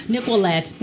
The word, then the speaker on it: Nickquolette: Nickquolette.